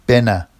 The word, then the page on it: bena: Vafsi